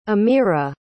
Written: ameerah

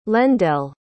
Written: lendell

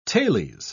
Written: tales

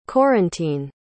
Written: Corentine

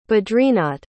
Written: badrinath

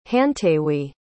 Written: hantaywee

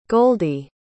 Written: goldy

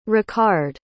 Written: Ricard

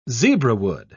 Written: zebrawood

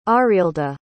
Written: arilda

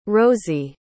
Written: rosey

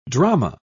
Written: drama

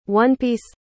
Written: one-piece

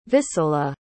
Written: visola

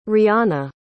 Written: rihanna

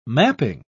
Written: mapping

